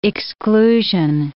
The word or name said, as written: exclusion